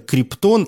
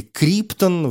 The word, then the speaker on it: Криптон: Его биологические родители отправляют парня на Землю как раз в тот момент, когда его родная планета планета Криптон или Криптон, в русском языке используют разные ударения, эта планета взрывается.